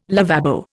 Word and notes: lavabo: Indian

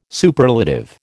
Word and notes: Superlative: American English

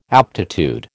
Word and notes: aptitude: American English

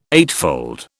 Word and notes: eightfold: British English

